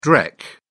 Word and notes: dreck: British English

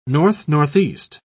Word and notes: north-northeast: British English

